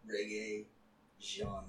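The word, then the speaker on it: Regé-Jean: Reggae Jean Page.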